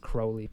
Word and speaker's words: Crowley: But Ozzy Osbourne like wrote the song Mr. Crowley and whatever, or apparently it's pronounced Crowley,